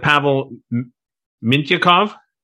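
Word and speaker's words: Pavel Mintyukov: Because I'm going to start with, and correct me on the pronunciation of some of these names, I'm going to start with Pavel Mintyakov. – Yeah, there you go.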